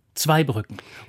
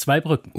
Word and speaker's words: Zweibrücken: Aber Zweibrücken lautet Zweibrücken und nicht Zweibrücken.